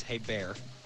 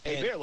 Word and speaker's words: Herbert: Well, first of all, it's Hebert. – Oh. Hebert, like Bobby Hebert from the Saints. – There you go. – It's not spelled like that. It's H-E-R-B-E-R-T.